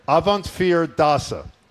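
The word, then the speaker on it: Avondvierdaagse: Avant, what's the middle? – Avantfear, the number four, fear. – Avantfear Dasa.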